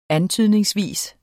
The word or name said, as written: antydningsvis